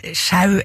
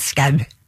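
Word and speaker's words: Schous: Og uansett, ja, altså, jeg tror virkelig ikke det noen grunn til å uttale det ølle som hverken sjau eller show eller noe som helst annet enn skau.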